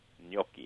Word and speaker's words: gnocchi: Gnocchi, yes.